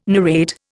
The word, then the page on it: narrate: Indian